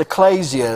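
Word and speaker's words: ecclesia (ἐκκλησία): which you may have heard it pronounced ecclesia. Uh, it's ecclesia is how it's actually pronounced, but ecclesia is where we get the direct definition of church.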